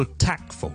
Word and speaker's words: tactful: 琴日咧，我哋讲到 tactful 呢个字，tactful 呢个字。咁啊，读到 tactful 咧，我通常咧都会同啲同学咧讲解一下个发音，因为 tactful tactful 有个 t 喺度嘅，但系好多时候咧，我哋都系唔读嘅。咁 tactful 就好似 tak 跟住 ful 咁。